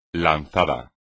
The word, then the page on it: lanzado: espagnol